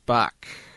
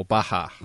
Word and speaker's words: Bach: And final thing for this week, Classicaloid episode 12, JS Bach, or Baha, depending on which character is pronouncing his name.